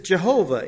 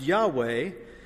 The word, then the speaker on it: Yahweh: This is Jehovah in the King James Version of the Bible, but it might be more properly pronounced Yahweh, though the pronunciation is uncertain.